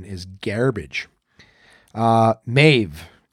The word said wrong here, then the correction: mauve: M-A-U-V-E. It once rhymed with stove, but now the O-A-U is sounded as ah. Mauve? So it was mauve? Oh, mauve, like the color.